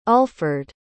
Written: ulfred

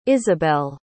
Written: isabel

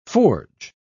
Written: forge

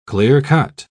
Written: clear-cut

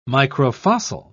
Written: microfossil

